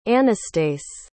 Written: Anastase

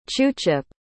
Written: chuchip

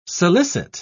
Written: solicit

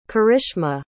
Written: karishma

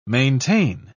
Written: maintain

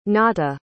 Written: nata

